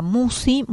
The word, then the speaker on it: Musci: Y en el canal de YouTube, en la semana, lo compartimos con imagen también, en el canal que lleva mi nombre, Marisa Musi. Musi se escribe M-U-S-C-I latina, la lista de reproducción, Serenamente.